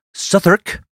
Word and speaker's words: Southwark: Born on December 28th, 1985 in Southwark, I think I'm saying it right, spelled Southwark, but I saw people getting mocked in comment sections for saying Southwark, Southwark, London, Meredith Susanna Cara Kircher, known as Mez to her friends, was a year and a half older than Amanda.